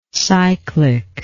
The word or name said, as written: cyclicity